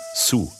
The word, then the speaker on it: Sioux: Sein Stamm sind die Sioux, eigentlich werden sie Sioux ausgesprochen.